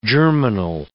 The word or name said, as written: germinal